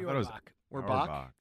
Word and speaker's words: Orbach: John, he passed away a few years back, but Jerry Orbach was, how do you say his name? Orbach? – Jerry Orbach. – Orbach. Yeah. Not Orville Redenbacher guy.